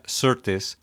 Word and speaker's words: Sirtis: Y quiero mencionar en este momento que una actriz que es tocaya tuya, la actriz que se llama Marina Sirtis, o como se pronunciaría en inglés, Marina Sirtis,